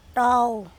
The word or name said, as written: Tao